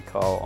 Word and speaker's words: Keil: And that's K-E-I-L. – Yes, it's a doozy. – Because I know, I was like, that doesn't pronounce it. Nope, it doesn't. It's German. It's old school German. It's a tricky one.